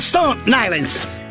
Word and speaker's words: Stone Island: Questi jeans della Stone Ainsel. – Di Jim, che c'è la questi jeans della Stone Islands.